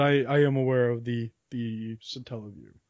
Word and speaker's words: Satellaview: There was also, and this is the coolest accessory I think, Japan only, the satellite view. Do you know about this one? – I believe it's called the Satella View. – Sorry, that's how you pronounce it.